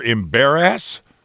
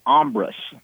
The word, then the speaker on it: Embarrass: is the name of the river Embarrass? – That's what it looks like. It's actually pronounced Ombrus.